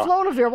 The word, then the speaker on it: Flodavieur: F-L-O-D-A-V-I-E-U-R. – Flodavir. – Yeah, Flodavir.